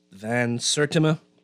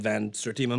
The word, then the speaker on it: Van Sertima: Let's say it's pronounced Van Sertima. Okay. I said Van Sertima, my bad.